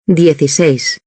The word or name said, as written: 16